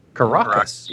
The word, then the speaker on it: Caracas: Caracas? – Caracas, yes, LR4.